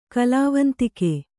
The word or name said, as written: ಕಲಾವಂತಿಕೆ